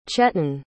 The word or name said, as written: chetan